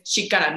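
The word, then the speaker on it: Chikara: Y bueno, eh, me equivoqué en la pronunciación, es Shikara, no Shakari, como había dicho,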